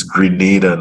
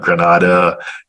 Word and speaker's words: Grenada: Eh, it's Dominica, it's Grenada, not Granada, you know, stuff like that.